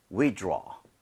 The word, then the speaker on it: withdraw: 발음할 때는 draw가 아니고요. aw라는 스펠링은 보통은 이 모양을 벌려주셔야 돼요. – 네. – So, 이렇게 한번 따라해 보세요. withdraw.